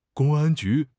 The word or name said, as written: 公安局